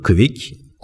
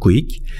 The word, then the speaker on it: Kwik: Sınırlar varsa senin için diyor, quick, quick, bir şekilde bu soyisim de K-W-I-K Quick diye okunuyor herhalde.